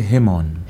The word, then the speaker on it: Hemon: How do I actually say his name properly? – I say Hemon.